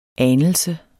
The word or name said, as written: anelse